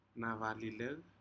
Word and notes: naralilec: Aneityum

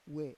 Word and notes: we: Roon

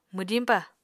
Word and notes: mwdiñpa: Apatani

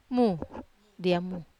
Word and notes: mu: Wamesa

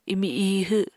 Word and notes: imi ihw: apatani

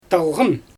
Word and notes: talɣɯn: Xyzyl

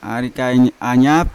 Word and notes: arikain anya: Yawa

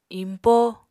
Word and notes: iñpó: apatani

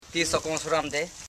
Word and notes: sokosiramde: so.ko.si.ram.de; Koro

